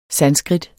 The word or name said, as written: sanskrit